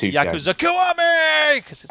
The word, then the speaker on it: Yakuza Kiwami: You need to say it like this: Yakuza Kiwami.